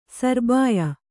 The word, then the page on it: ಸೌರಭ್ಯ: saurabhya